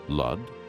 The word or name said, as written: Lud